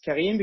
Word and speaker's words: Karim: So my name is Karim. I pronounce it as Karim because I'm French-Lebanese.